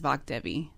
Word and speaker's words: Vak Devi: So my DJ name is Vak Devi. And she is the spirit goddess of music and language. – And how do you spell that? It's V-A-K-D-E-V-I, Vak Devi.